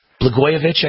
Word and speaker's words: Blagojevich: Blagojevich, I guess I pronounce Blagojevich.